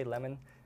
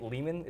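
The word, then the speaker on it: Leman: I don't know, some people say Lemon. I think Lehman is the right way to say it.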